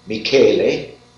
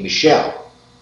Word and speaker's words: Michele: That's real Italian name was pronounced Michele, and it is spelled the same as we spell our lady's name, Michelle. M-I-C-H-E-L-E.